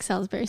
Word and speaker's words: Salisbury: Salisbury. S-A-L-I-S. – Yeah, it's like Salisbury steak. – Yeah, so it's a tough one for Americans or anybody that isn't English, but yeah, it's pronounced Salisbury.